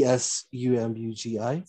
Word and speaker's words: Tsumugi: Uh, um, excuse the tra- excuse the pronunciation, um, but it's Sumugi. – Is right. the T S U M U G I? – Sumugi, yeah, great.